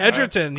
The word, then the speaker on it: Egerton: Taron Egerton. – Oh. Is that ah. right? Edgerton. Yeah, Edgerton. that was one of yeah.